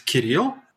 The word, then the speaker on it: Kirill: Is that the actual original way to say this is Kirill.